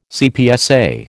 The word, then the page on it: CPSA: American English